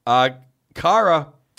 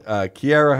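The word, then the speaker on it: Zdeno Chara: Chara, however you say it, not Chara, but Kiara, however you say his name, the guy in Edmonton run Shillington, and then fought Richie, and Richie knocked him out.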